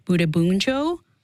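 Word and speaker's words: Bundibugyo: James, to Shashank's point about this outbreak is caused by a rare, very deadly strain of the Ebola virus called Budabunjo. Did I say that correctly? I got it.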